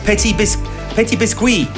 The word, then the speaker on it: Petit Biscuit: So the brilliant Ben Boma with Little Lights, and before that was Petit Biscuit, if we're going to pronounce it like the French do, and Sunset Lover, chosen by Alex in Hertfordshire, who sounds like he comes from a whole family of Sunset Lovers.